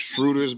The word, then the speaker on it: Schroeder: I don't know if Schroeder's back. I mean, that motherfucker had COVID last week or a week before that or something. – I do appreciate the correct German pronunciation.